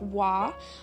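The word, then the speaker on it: Vau: So starting out with correspondence, the Hebrew letter that is associated with the Hierophant is pronounced wa, it's V-A-U,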